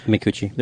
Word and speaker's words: Micucci: Micucci.